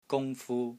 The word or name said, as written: GUNG1 FU1